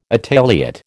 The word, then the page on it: Italiot: American English